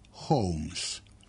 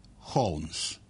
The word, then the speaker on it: Holmes: Holmes. Ali ima nešto čudno u svemu tome. Podsjetit ću vas šta tačno kaže transkripcioni rečnik engleskih imena. Holmes ili Holmes po američkom izgovoru.